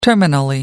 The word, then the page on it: terminally: British English